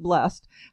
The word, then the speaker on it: Blest: And as Adele mentioned, it is spelled B-L-E-S-T, almost like best, but blessed.